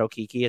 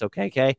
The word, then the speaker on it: Okeke: Chuma Okeke, who I believe wants us, he found out his name was pronounced differently, so I'm trying to pronounce that correctly. It's not Okiki, it's Okeke.